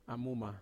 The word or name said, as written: amuma